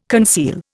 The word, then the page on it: conceal: Indian